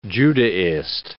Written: Judaist

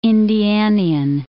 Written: Indianian

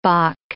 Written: bock

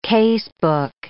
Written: casebook

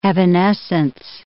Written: evanescence

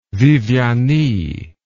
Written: viviani

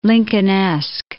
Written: Lincolnesque

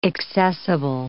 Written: accessible

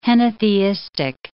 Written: henotheistic

